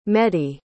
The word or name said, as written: mehdi